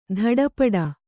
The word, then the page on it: ತಾಟಿಪೋಟಿ: tāṭi pōṭi